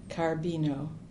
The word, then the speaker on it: Carbino: Rosemarie Carbino, C-A-R-B-I-N-O.